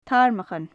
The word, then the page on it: tarmachan: gaelic